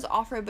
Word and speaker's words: Offred: But, like, her name was Offred, but she, or I don't even know. I was pronouncing it Offred, but I feel like it's just of Fred.